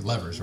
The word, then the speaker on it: levers: And they're a little bit like, they say levers. How do you say it here? Levers, right? – Levers. – Levers. Sorry.